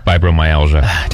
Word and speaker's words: Fibromyalgia: It's also, I always mess up how to say this, National Fibromyalgia? – Fibromyalgia.